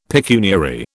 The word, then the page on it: pecuniary: British English